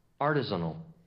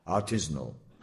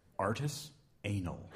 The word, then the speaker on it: artisanal: Artisanal. – Pronunciation B. – Artisanal. – C. – Artisanal. – And D. – Artist anal.